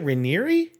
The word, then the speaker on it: Raniere: Do they pronounce it Raniere? – Yes, apparently it's Raniere.